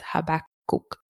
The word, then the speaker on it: Habakkuk: Probably the hardest name to say in the Bible, honestly, but we got the pronunciation. Mary and I took some time to look it up and kind of talk through it, and it's Habakkuk.